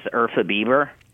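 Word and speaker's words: Urfa biber: Ufra Bieber, or is it Biber? – I pronounce it as Urfa Bieber, but okay. It's not related to Justin Bieber.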